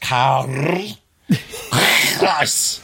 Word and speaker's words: Zeiss: So Karl Zeiss. – Yeah, but that's actually pronounced Karl Zeiss.